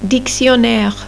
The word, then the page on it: un dictionnaire: French